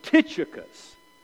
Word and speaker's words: Tychicus: And he pronounced it Tychicus. And so I'm going to call him Tychicus.